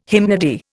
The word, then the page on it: hymnody: Indian